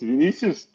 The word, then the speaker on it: Vinicius: It was a very good pronunciation. It's Vinicius.